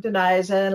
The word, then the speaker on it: Denisa: So the French Denise became Denisa, and Louise became Louisa. It's literally just Kentucky by French.